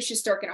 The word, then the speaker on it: Shesterkin: Because for some reason, Shesterkin gives the Devils give Shesterkin a hard time.